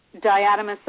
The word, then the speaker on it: diatomaceous: And then diatomaceous earth. – Did I say that right? – Diatomaceous.